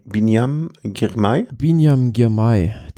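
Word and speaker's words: Biniam Girmay: Also am Ende war es dann eine Massenankunft mit, wie spricht man den genauso aus? – Binyam Girmay. Der englische Kommentator sagte, Beanie being back.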